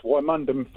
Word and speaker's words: Wymondham: Yes, Wymondham for those who say it properly. That's a good old Norfolk term, but Wymondham.